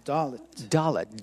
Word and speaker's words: Dalit: Dalit. – D-A-L-I-T. – D-A-L-I-T.